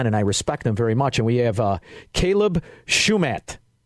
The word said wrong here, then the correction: Shumate: Is that how you say it? That's probably not it. Shumate. I think it's Shumate. What is it, Caleb? Shumat or Shumate? – Caleb Shumate. – Shumate. I should have went with that in the first place.